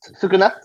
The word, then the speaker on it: Sukuna: Su- sukana's uh is it uh, Plus? – Sukuna, Sukuna. Sukuna's uh, fingers and power.